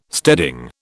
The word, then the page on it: steading: British English